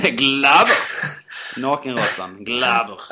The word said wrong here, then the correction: glaber: Ja, det uttalas ju definitivt inte som jag sa det. Varför körde jag någon tysk accent på den? Det var jävligt onödigt. – Glaber. – Glaber.